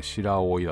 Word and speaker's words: 白老: 白老って読むの？ – 白いにオイルで白老だと思います。